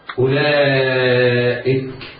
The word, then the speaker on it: أولئك: لو نظرت إلى مصحفك لوجدت أولئك همزة قطع مضمومة بعدها واو. هذه الواو عليها دائرة، ومعنى ذلك أن هذه الواو لا تنطق بحال من الأحوال. إذن ننطق همزة مضمومة بعدها لام مفتوحة أولئك، لا إياك وأن تنطق هذه الواو، فهذا لحن جلي وخطأ فاحش.